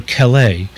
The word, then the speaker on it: Calais: So we've decided to name her Calais. – And the vet is having trouble spelling it and pronouncing it. – C-A-L-A-I-S, yeah, Calais.